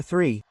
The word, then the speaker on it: three: Where's the TH sound there? When we pronounce it like this three, then it's three. Number three. Three. Listen carefully. Three. Number three. That's what I said in the previous class, and that's what I still say.